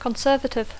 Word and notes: conservative: English

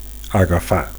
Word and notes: agafar: Catalan

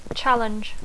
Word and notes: challenge: English

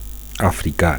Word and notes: africa: Catalan

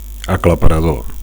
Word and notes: aclaparador: Catalan

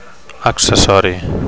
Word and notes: accessori: Catalan

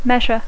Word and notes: measure: English

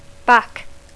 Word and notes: back: English

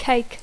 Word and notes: cake: English

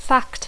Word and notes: fact: English

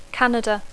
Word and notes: Canada: English

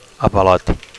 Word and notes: avalot: Catalan

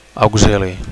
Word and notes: auxili: Catalan

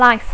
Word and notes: life: English

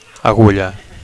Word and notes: agulla: Catalan

